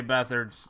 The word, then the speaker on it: Beathard: Beathard, I'm sorry, kid. – It's pronounced beat hard, but we'll see what happens.